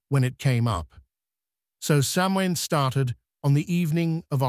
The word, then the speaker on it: Samhain: The ancient Celtic people of Ireland celebrated a festival called Samhain, pronounced Samhain, which created the rebellious dark spirit that still haunts Halloween today.